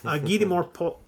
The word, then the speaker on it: Guy de Maupassant: I've started making notes on an episode, a little brief episode I'm going to put out in October, focusing on Guy de Maupassant's, I just mispronounced his French name, Guy de Maupassant.